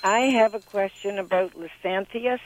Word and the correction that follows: Lisianthus: I don't know if I'm pronouncing that correct or not. L I S I A N T H U S, the beautiful little blue flower. – Lisianthus. – Yes. – Yep, got it.